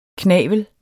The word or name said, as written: knavel